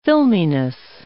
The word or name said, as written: filminess